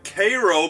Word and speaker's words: Cairo: So Grant set up his command at Cairo, Illinois, which is spelled like Cairo, Egypt, but pronounced like Cairo because fuck it, we're Americans.